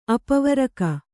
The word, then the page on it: ಅಪವರಕ: apavaraka